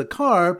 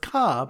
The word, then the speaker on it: car: So the Siman Daf Chafav is a car pronounced ka by people from Boston.